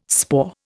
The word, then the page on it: spoor: Indian